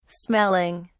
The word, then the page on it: smelling: British English